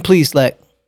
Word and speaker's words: Plesac: Clevinger and Plesac, that's how you say his name.